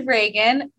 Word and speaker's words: Regan: So podcast, Real Talk with Regan. My name is spelled R-E-G-A-N.